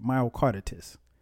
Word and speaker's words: myocarditis: I think I pronounced that right. Myocardit, myocarditis. That's it, myocarditis.